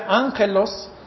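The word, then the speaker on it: ángelos (ἄγγελος): Ángelos, que está de letra A-G-G-E-L-O-S, es palabra griega, y esa palabra se pronuncia ángelos, y quiere decir traer buenas noticias.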